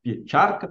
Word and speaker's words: Pieczarcka: Eu me chamo Lucas Piecharca, peço desculpas pelo sobrenome, pronúncia não é das mais fáceis, mas é meu nome, enfim.